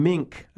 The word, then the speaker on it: Minck: I received an interesting letter from a gentleman named John Mink, I think is how you pronounce it, M-I-N-C-K, John Mink.